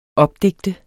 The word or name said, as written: opdigte